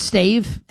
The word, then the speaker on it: stave: Isn't it stave? S-T-A-V-E? Is it pronounced stav? – Oh, did I say stav? I meant stave.